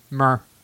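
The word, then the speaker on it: myrrh: It's M-Y-R-R-H. Meyer? – Mer. – Mer. – Is that how you spell mer? – I got the mer.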